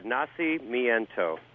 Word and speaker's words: Nacimiento: Yeah, Nacimiento.